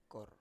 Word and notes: kor: Biak

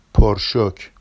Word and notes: porʃoc: Vafsi

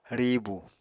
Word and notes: riibu: Apatani

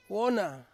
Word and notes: wona: Ansus

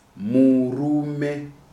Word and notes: Murume: Shona

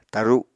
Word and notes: taru: Apatani

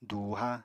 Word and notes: dùuha: apatani

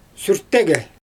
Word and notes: syrtege: Xyzyl

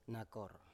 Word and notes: nakor: Biak

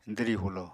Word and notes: dwri-hulo: apatani

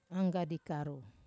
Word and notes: anggadi karu: Wamesa

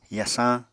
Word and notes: yasañ: apatani

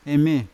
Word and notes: eme: Yawa

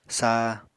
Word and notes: sa: Apatani

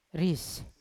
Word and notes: ris: Yawa

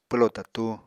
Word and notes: pwlo tatu: Apatani

